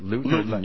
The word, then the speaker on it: Luton: Is that how you pronounce that? Luton. – Yeah.